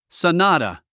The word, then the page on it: sonata: sənάːtə; ソナタ